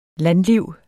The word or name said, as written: landliv